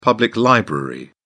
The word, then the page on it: public library: British English